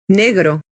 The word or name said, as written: negro